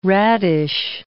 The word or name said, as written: radish